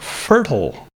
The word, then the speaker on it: fertile: Our first word, fertile. Fertile has an sound at the first syllable and then a schwa sound for the second syllable.